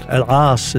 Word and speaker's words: Elass: Elass is in Arabic, but we say in English just Elass.